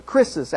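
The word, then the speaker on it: krisis (κρίσις): It's the Greek word crisis, or krisis, actually, is how it's pronounced in Greek, which we've retained in the English with only a slightly change in spelling.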